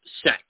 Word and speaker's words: sect: Apparently, she's been brainwashed into thinking that eco-villages are religious sects. – Right. – Sects. How do you spell that? S-E-C-T. – S-E-C-T. – Yeah. Not S-E-X, but S-E-C-T. – S-E-C-T.